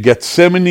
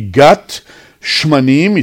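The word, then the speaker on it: Gat Shmanim (גת שמנים): Again, Gethsemane is really not right way to say it, as we in Hebrew, in Israel, say. It's called really Gat Shmanim.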